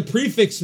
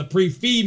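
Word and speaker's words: prix fixe: But if you get the prefix meal, as she calls it, or the pre-fee meal, as it's fucking pronounced, then you do get an appetizer because it comes with the pre-fee.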